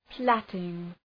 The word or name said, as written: plaiting